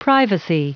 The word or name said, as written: privacy